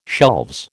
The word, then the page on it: shelves: American English